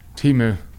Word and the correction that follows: Temu: I always say it wrong. And people, what is it? – Temu. It's supposed to be. – Tamu? – Temu.